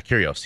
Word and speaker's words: Kyrgios: That's how you pronounce his name, right? – Yeah, Kyrgios.